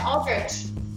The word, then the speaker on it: Aldrich: But that's our friend Aldrich. – Yeah. The H is silent.